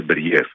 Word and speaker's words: Μπεριέφ: θα είχαμε τον Μπέριεφ. Μπέριεφ, πού τονίζεται πρώτα απ' όλα για να το λέμε και σωστά? – Νομίζω το λένε Μπεριέφ.